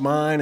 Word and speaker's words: Main: It's pronounced mine and spelled Maine, and I'm a Hoosier, and that did not make one lick of sense to me.